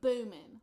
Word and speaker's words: Boomin: Metro Boomin. – Metro Boomin. So. – Metro Boomin. – Boomin? – Mm-hmm. – Boomin. Mm-hmm. Like. Can you spell that? – B-O-O-M-I-N.